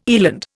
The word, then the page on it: eland: Indian